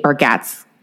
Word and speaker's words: Bargatze: Do you know the comedian Nate Bargatze? – I don't. – Is how you say his name.